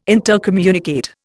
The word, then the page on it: intercommunicate: Indian